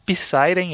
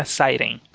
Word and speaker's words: Psyren: a maioria das pessoas fala Psyren, eu também falava por um tempo, mas em inglês é. se fala o P, o P é mudo, então é Siren.